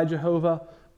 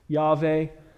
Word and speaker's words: Yahweh: I don't think God is offended by Jehovah, but I believe more accurately is pronounced Yahweh.